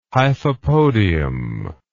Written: hyphopodium

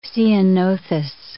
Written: ceanothus

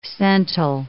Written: cental

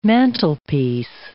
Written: mantelpiece

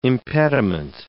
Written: impediment

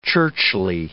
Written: churchly

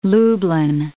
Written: Lublin

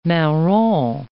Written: marron

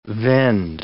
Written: vend

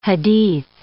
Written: hadith

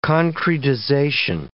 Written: concretization

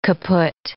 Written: kaput

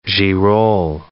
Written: girolle

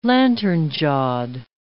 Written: lantern-jawed

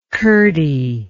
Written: curdy

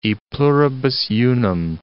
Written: e pluribus unum

